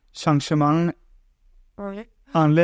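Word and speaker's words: Saint-Germain-en-Laye: Hej Amalie, tak fordi du var kommet og besøge dig i Saint-Germain Anle, det er som man udtaler det. – Det er i hvert fald sådan, jeg udtaler det.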